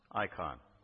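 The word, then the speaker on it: icon (εἰκών): The image of God, the image in the Greek is pronounced icon. E-I-K-O-N, icon.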